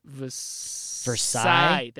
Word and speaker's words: Versailles: Versailles.